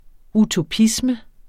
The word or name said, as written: utopisme